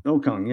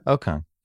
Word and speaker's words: Okung: Russell Okung. Okung? How do you say his name? I always get this wrong. – Okung, yeah. – Okung.